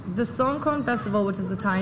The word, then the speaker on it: Songkran: Now, we had talked before, and I definitely want you to get into the Songkran. Am I saying that right? – Songkran. – Songkran, yes.